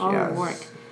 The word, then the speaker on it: O'Rourke: O'Rourke.